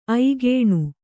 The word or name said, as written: ಐಗೇಣು